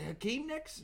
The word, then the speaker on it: Hakeem Nicks: Is it Hakeem Nicks? Yep. Am I saying that right? Yep.